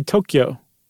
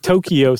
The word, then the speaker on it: Tokyo: Or I should say Tokyo. I've been saying it wrong.